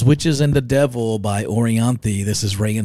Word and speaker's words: Orianthi: But it's the girl that played with Michael Jackson, Orianthi.